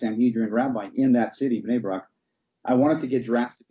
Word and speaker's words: Bnei Brak: Bnei Brak. You pronounced it perfect, Mike. Bnei Brak, Israel.